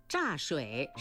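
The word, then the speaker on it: 柞水: 乍蚕丝错了，应该是坐蚕丝。听众评判正确。木字旁右边放个初来乍到的乍，是个多音字，分别读乍和坐。乍水是一个地名，在陕西省。坐是树名，坐树也就是栗树，叶子可以喂坐蚕。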